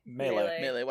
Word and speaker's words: melee: Melee weapons.